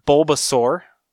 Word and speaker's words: Bulbasaur: And then also coming to Amazon, we got the Flocked Bulbasaur.